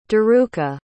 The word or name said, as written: daruka